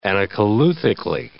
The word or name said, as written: anacoluthically